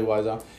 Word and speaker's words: Wiesel: Eli Wiesel.